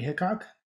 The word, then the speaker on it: Hickok: So that's at Gregory Hickok.